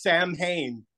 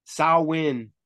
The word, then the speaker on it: Samhain: But now they're like, oh, it's how do you say it? – Samhain. – Samhain. – Samhain, it's Celtic, know.